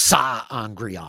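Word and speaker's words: sa'angreal: And I thought that the way that that you see him pouring all of his power into the Sangreal. Am I pronouncing that right? – So Angreal. Yeah. And then there's Sa Angreal.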